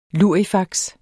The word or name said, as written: lurifaks